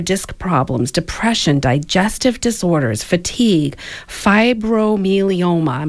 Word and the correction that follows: fibromyalgia: Fibromyalgia, gastrointestinal disorders, headache, hypertension, immune system, infertility, insomnia, labor inducement, mm-hmm.